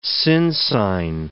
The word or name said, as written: sinsyne